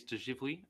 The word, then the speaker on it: de Givry: Felix de Givry, I believe. – De Givry. Okay. – Yeah. De Givry. – I'm going to leave that to you. – Soft G. – Soft G.